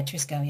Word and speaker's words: Triskele: I also noticed that you'd been, now I'm probably going to pronounce this incorrectly, is it Triskele Litfest? – Yeah, Triskele, yeah.